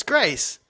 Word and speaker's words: Grayce: No, it's Grace, but it's spelt with a Y in the middle.